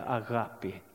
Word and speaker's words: agapi: Men det heter på grekiska agapi. Det är svårt med uttalen.